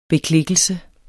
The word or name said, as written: beklikkelse